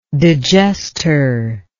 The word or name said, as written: digestor